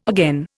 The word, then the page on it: again: Indian